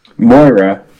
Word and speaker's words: Moira: Did I say her name wrong? – Oh, I thought you were saying that was the Build-A-Figure. How do you say her name? – Moira. – Moira. Okay.